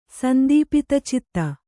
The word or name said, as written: ಸಂದೀಪಿತಚಿತ್ತ